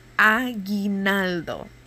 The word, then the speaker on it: aguinaldo: So, I cannot say that carols are the one thing that brings us back, but we do have something that's called aguinaldo. So aguinaldo. – Let me just write it down so I can spell it. Okay, yeah, I have it here. So it's A-G-U-I-N-A-L-D-O. So that's aguinaldo. So an aguinaldo, there are two versions.